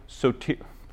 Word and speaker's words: soteriology: Soteriology.